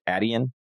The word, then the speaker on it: Adyen: but like how do I say this company properly? Is it Adyen? – Yeah.